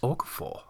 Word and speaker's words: Okafor: We needed a defensive end, obviously, and Alex Okafor.